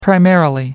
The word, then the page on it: primarily: American English